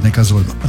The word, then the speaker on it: Neckarsulm: Also, es ist am Zusammenschluss von Neckar und Sulm. – Okay. – Und deswegen heißt die Stadt Neckarsulm.